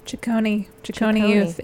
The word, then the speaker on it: Ciccone Youth: Ciccone Youth.